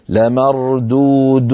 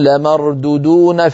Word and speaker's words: لمردودون: المثال الثاني الذي نراه على اللوحة، لمردودون في الحافرة، هكذا صواب نطقها، لمردودون، فبعض إخواننا من السرعة يقولون لمردودون في الحافرة فيخطف الواو الأولى ولا يمدها.